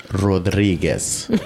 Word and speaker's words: Rodriguez: Yeah, it's pronounced Rodriguez.